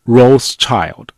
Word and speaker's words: Rothschild: 免贵红牌那么这个 Rothschild 按照英语发音习惯就是 Rothschild。